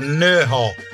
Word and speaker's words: Nerja: I was in a beautiful little town city, big town called Nerhaw. It's spelled Nerja.